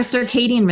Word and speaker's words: circadian: Is that how you pronounce that word? – Yeah, our circadian rhythms.